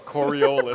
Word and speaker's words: Coriolis: Well, like, is it like, you know, his approach to science stuff, like he did Coriolis effect, is that how you say it? – Yeah. – Coriolis? – Coriolis.